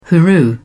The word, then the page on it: hooroo: British English